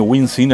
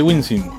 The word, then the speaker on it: Wisin: Bueno, decía, hay alguien que me está peleando acá, porque dice que pronuncio más Wisin. Wisin. Acá dice Wisin. Bueno, está bien. Wisin, dice que bien.